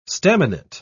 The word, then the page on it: staminate: British English